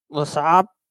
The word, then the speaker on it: Les Sables: How do you say that? – LeSab, 70.3 LeSab.